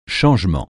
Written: changement

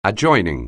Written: adjoining

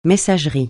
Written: messagerie

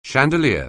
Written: chandelier

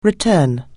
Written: return

